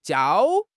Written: jiao1

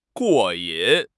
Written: 过瘾

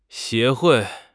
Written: 协会

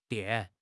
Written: 垫

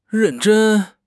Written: ren4 zhen1

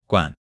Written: guan3

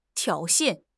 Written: 挑衅

